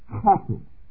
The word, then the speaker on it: chesed: The first word is chesed. Chesed, all right? If you really want to know how to pronounce that, that first sound is the sound of a camel spitting.